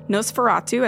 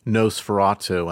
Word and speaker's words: Nosferatu: So you say Nosferatu, and I say Nosferatu. Nosferatu. Which is right? Am I right? – It's probably Nos, because isn't that more the German? It comes from the German pronunciation. – Yeah. – And I think that's probably Nosferatu.